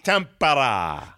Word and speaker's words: Tampere: How do you pronounce Tampere? Is it Tampere? – Tampara. – Tampara.